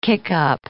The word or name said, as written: kickup